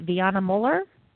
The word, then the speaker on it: Viana Muller: Viana Muller? – Perfect.